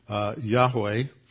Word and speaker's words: Yahweh: That means that Lord is a translation of the personal name for God in the Old Testament, represented by four letters, YHWH, usually pronounced Yahweh.